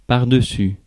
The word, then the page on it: pardessus: /paʁ.də.sy/; France